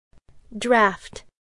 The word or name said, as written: draft